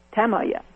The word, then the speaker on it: Tamaya: I just learned how to say this differently. Tamaya. I'll say it the old way. Hyatt Tamaya. Tamaya. There we go.